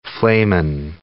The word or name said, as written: flehmen